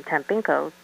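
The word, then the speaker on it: Tampinco: Last name, Tampinko, Tango, Alpha, Mike, Papa, India, November, Charlie, Oscar.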